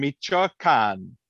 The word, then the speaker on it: Michoacán: It came from a place with an accent on the last syllable that I can't probably say, but I'm going to try anyway. It came from Michoacán.